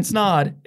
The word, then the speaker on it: Snod: My name is Ryan Snod. It rhymes with odd, and you're watching and listening to the Rhymes with Odd podcast.